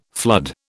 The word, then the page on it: flood: British English